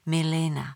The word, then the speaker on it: Milena: Kafka se doslova mazlí s českým jménem Milena, jež by však jakožto Němec vyslovoval nejradši s přízvukem na druhé slabice, Milena, nikoli jako Čech s přízvukem na i v první slabice.